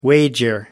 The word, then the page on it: wager: American English